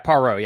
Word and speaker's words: Poirot: Yeah, Poirot, yeah.